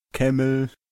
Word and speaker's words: Camel: Da waren Jody, Camel.